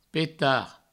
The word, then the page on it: petar: Provençal; peta